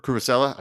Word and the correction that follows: Cucurella: I forget how to pronounce his name. – Yeah. Cucurella. – Cucurella. Yeah, sorry.